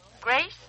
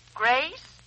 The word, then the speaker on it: Grace: Grace Stevens. – Huh? Who? – Grace Stevens.